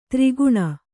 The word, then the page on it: ತ್ರಿಗುಣ: tri guṇa